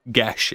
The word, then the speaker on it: Geas: Gesh. It's pronounced Gesh. It's spelt G-E-A-S.